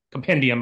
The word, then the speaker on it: compendium: I just bought them, put them in there, but then I read them from the compodium. So a compendium, I guess is how you pronounce it.